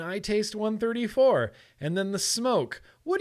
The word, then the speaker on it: Smok: I feel like Smoke Tech, which once upon a time, Smok or Smoke was called Smoke Tech. Smoke Tech. And that's why I know how to pronounce it Smoke instead of Smok, because they used to call themselves Smoke Tech.